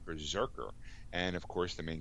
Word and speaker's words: BRZRKR: Boom Studios has released more artwork for the Keanu Reeves and Matt Kent's October debuting 12-issue comic series called Berserker. It's spelled BRZRKR.